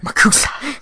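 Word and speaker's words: MACUSA: Try and say it correctly. – Makotsuosuwa. – No. Like, try and really say it correctly. – Makusa. – Ooh, say it again. – Makusa. – There. It took a while to get there, but it was worth it.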